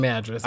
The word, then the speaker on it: Madras: I don't like the name Madras because it sounds like mattress, but it's spelled like Madion.